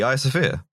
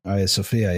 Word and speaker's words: Hagia Sophia: How do you say Hagia Sophia? – Yeah, the Hagia Sophia. – Hagia Sophia, yeah. – Is that really how you... – That's really how you say the Hagia Sophia.